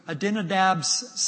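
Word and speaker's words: Abinadab: So Adinadab's sons, let me pronounce his name correctly, Adinadab, yes, his sons, Uzzah and Ahio, are guiding the cart.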